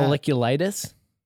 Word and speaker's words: folliculitis: Am I saying that right? Folliculitis. How do you how do you pronounce that? Folliculitis. Yeah, folliculitis.